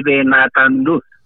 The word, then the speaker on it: Benatanduz: Pues bueno, no lo has dicho muy bien porque es Cañada de Benatanduz. Pues ahí queda, Cañada de Benatanduz.